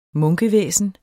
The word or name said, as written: munkevæsen